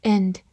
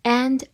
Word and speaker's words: and: employ 动词雇佣，employ。end，a n d，end，字母 a 发大口的 a，and，在弱读的情况下也可以读作 and，或者末尾的 d 也可以省略 n。它是一个连词，表示和与并且。比如说，a book, a pen and a ruler，一本书、一支笔和一把尺子。